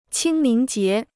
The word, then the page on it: 清明节: qīng míng jié